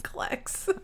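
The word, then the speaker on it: Klecks: Klex. – I mean, I don't know if I'm saying it right or not. – Yeah, you are.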